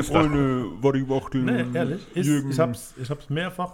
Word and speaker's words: Waddy Wachtel: Spricht man denn dann Waddy? – Nee, Waddy. – Also, Waddy.